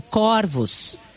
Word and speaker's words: corvos: Estranhei que a palavra corvos, foi pronunciada com o O fechado. Olha lá, igual eu. Pesquisei e descobri que, diferentemente do singular, no plural, a palavra corvos é pronunciada com o O aberto, corvos.